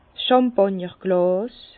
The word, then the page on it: 's Chàmpàgnerglàs: Bas-Rhin; Herrlisheim